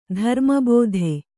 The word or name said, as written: ಧರ್ಮಬೋಧೆ